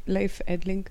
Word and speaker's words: Leif Edling: They had the bass player, Leif Edling. Did I say his name right? Yeah, Leif Edling.